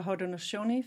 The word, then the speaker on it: Haudenosaunee: So the name is the Haudenosaunee Festival. That means People of the Longhouse. It references the Haudenosaunee, also sometimes known as Iroquois Confederacy.